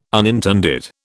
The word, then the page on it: unintended: British English